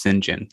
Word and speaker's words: St. John: Yeah, and it's pronounced St. John. That's how you say his name.